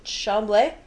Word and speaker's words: Chambly: Blanche de Chambly. Chambly? – Yep. – Sure is.